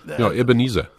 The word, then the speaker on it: Ebenezer: Ebenezer.